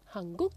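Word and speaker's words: hanguk (한국): Y luego el Corea como país se pronuncia hanguk. – Hanguk.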